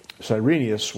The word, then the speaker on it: Cyrenius: The verse reads like this, and this taxing was first made when, this isn't the Greek pronunciation, but how we usually read it in English, Cyrenius was governor of Syria.